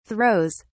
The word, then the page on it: throws: British English